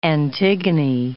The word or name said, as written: Antigone